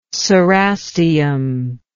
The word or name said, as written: cerastium